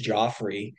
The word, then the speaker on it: Jeffrey: And you know, there's a guy from Louisville, his name is Joffrey, spelled Jeffrey, but it's pronounced Joffrey, ran the number two all-time performance in the steeplechase at the Brian Clay Invitational.